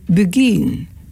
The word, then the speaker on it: beguine: En je spelt het B-E-G-U-I-N-E. De Bulgin. Zo spreek je het uit.